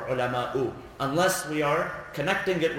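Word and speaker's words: العلماءُ: Now what's the proper way to pronounce it? إِنَّمَا يَخْشَى اللَّهَ. Is it مِنْ عِبَادِهِ الْعُلَمَاءَ or ulama'u? Ulama'u.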